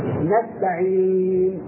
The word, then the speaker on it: نستعين: فإذا وقفنا على نستعين بالإسلام نقول ماذا؟ كيف ننطقها؟ نستعين نستعين. أيوة، لما وصلنا إلى النون التي سكناها ماذا فعلنا؟ ماذا صنعنا؟ جمعنا الشفتين، يعني كأننا نشير إلى الضمة إشارة بدون نطق. نشير إلى الضمة إشارة بدون نطق، فنقول نستعين.